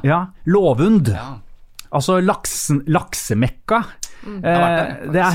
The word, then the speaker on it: Lovund: Altså, broren min bor på Låven, en liten øy ute i havgapet. – Gjør han det? – Rett ved Træna. – Ja, men uttales det Låven? – Låven. Und. Lovund.